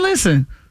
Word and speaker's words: listen: Listen is another one where the English words has a silent letter. – Silent and listen? – T. Listen. You don't hit no T in listen.